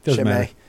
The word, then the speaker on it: Chimay: I'm going to pronounce it wrong now. – Chimay. – Chimay? – Yeah. – Probably. If I had to pick anything. – What have you pronounced it as? – Yeah. – Shema.